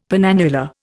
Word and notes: penannular: Indian

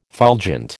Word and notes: fulgent: American English

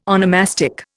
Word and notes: onomastic: Indian

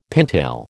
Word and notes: pintail: American English

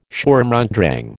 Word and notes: Sturm und Drang: American English

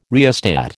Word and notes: rheostat: American English

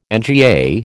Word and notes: NGA: American English

